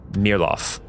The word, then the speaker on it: Mayerlof: Maerlof." And what it's spelled is M-A-Y-E-R-L-O-F. But as you say it, you hear Maerlof, which is the current capital of Via Severia.